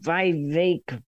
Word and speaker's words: Vivek: I was impressed with this guy named Vivek, rhymes with cake, Ramaswamy.